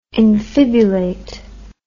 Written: infibulate

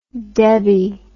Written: Debby

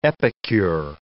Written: epicure

